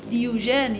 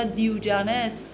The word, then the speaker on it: دیوژن: دیوژن یا دیوجانس، تلفظهای مختلفی داره به خاطر اینکه رومی یا یونانی یا لاتین صحبت مثلا تلفظ کنن، یک فیلسوف یونانی پیش از میلاد مسیح بوده که یه روز یه شمع گرفته بوده تو روز روشن تو بازار میچرخیده.